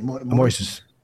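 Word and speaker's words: Moisés: When you think of Thiago, how do you pronounce his last name? – Moises.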